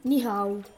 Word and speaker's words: ni hao: Ciao si dice ni hao.